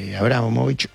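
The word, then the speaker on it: Abramóvich: Abramovich, sí.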